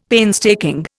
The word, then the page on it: painstaking: Indian